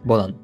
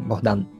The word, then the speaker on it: Bogdan: Bodan, sí, Bueno, es la versión un poco española de mi nombre. En realidad se pronunciaría como Bogdan, que es un poco más complicado Sí, sí.